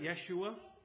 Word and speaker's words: Yeshua: The English spelling of this Hebrew word is Yeshua, spelled with a Y. You've all seen it. You all heard it. Yeshua.